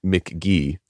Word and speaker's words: McGee: It's called Pronounced McGee, spelled M-C-G-E-E.